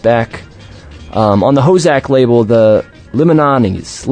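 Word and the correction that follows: Limiñanas: Limananas? How would you pronounce that? Limananis. – Limananas. – Limananas, thank you.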